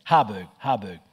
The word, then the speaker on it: Harburg: Here's a quote from Jarberg, I think is his name. I might have mispronounced that. Harburg.